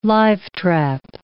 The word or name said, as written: live trap